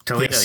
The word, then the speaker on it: Toledo: Toledo, yes. Not Toledo, Ohio.